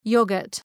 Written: yoghurt

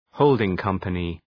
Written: holding company

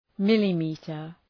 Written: millimetre

